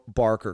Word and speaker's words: Barker: Almost said Baker, but nope, Barker, because I butchered the last name from the Larry Mutsunik from the last podcast that I did.